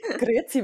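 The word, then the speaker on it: Grüezi: Hallo Annemarie, schön, dass du heute hier im Podcast bist oder wie man bei dir sagt, wahrscheinlich eher Grüzi, ähm, wenn ja. ich das richtig ausgesprochen habe. Grüzi miteinander, genau, ja.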